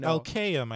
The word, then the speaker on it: Elkayem: Elkayem, I think, is how his last name is pronounced.